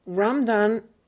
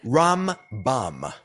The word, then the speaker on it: Rambam: So Ramdan, is that the right way to pronounce it? – Rambam.